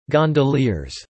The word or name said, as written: gondoliers